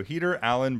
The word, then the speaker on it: Heater Allen: So Heater Allen Brewing. Did I get that right? – Yes.